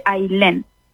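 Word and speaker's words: Aillén: ¿Se escribe A-Y-L-E-N? – No, mi nombre es uno de los pocos que conozco que se escriben A-I latina doble L-E-N. Se escribe Ailén y se dice Ailén.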